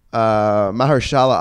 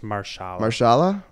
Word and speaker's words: Mahershala: Mahershala Ali, is that how you pronounce it? – I think it's just Marshala. – Mahershala.